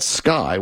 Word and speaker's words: Sceye: Says one of the objects may have been a large, shiny, metallic-covered blimp from an aerospace company out of New Mexico called Sky, which is spelled S-C-E-Y-E.